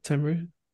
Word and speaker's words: Tenryu: First match is Tenryu. Am I pronouncing that right? Tenryu? – Yeah.